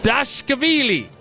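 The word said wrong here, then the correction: Dvalishvili: Close enough. Davalishvili. – Davalishvili. Merab Davalishvili.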